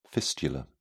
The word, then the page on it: fistula: British English